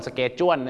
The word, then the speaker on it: schedule: schedule นะฮะชาวอังกฤษเนี่ยนะครับจะออกเสียงไม่เหมือนอเมริกาครับอเมริกาจะคล้ายๆของคนไทยครับก็คือ schedule ครับแต่ปรากฏว่าชาวอังกฤษหลายคนมากนะครับก็จะออกเสียงว่า schedule ครับผมชอช้างนะฮะ schedule นะฮะคล้ายๆชอช้างเชนะฮะเช schedule